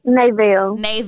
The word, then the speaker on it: Nevelle: Nevelle. – Okay, so you pronounce it Nevelle, like N-A-Y-V-A-I-L.